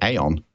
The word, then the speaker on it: Aion: And then the following week on the 8th of August, we've got Matthew Spoke, who's going to talk about, I think you pronounce it Aion, A-I-O-N.